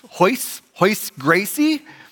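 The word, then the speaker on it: Royce Gracie: His name was, it looks like Royce, but it's pronounced Hoyce Gracie.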